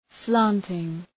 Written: slanting

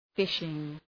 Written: fishing

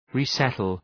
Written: resettle

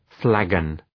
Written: flagon

